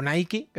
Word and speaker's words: Nike: Pues mira, ahora mismo tengo unos Nike, o Nike, que sería la pronunciación.